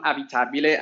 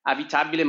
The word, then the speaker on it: Avitabile: So it's Kiran Avitabile, and Kiran is spelled C-I-A-R-A-N. Avitabile, my surname is A-V-I-T-A-B-I-L-E.